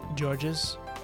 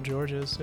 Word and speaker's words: Georges: How do you pronounce this name? It's George with an S at the end. – Georges. – Is that how you say it? – It's multiple Georges, yeah.